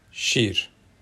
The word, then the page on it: şîr: Sorani sẖy̰r; shir